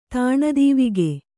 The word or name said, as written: ತಾಣಾದೀವಿಗೆ